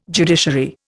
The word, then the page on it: judiciary: Indian